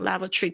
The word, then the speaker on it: lavatory: Lavatory 这边要特别注意一下，lavatory 也会有人讲 lavatory，特别英式发音那个 tory 都会讲成 tree 的声音非常多。那在美式发音的话，lavatory，lavatory，lavatory，OK，注意一下那个 t o r y 的声音，tree 或者 tree，像是 factory 啊，像那个工厂那个字，factory 或者 factory。